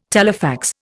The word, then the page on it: telefax: Indian